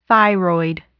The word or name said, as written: thyroid